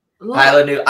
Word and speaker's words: Isla Nublar: The Ishna Nebler IPA. – I actually lost the coaster. – The I was like, am I having a stroke? – Isla Nublar. – Isla Nublar. I'm pronouncing it how it's spelled.